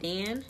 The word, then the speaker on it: Dan: In Hebrew, it's pronounced Dan, D-A-N, Dan.